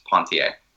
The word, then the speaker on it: Ponthier: It's spelled like Ponthier, P-O-N-T-H-I-E-R, okay. But it's pronounced Pontier. – And Louis Cato, is that C-A-T-O? – Yep.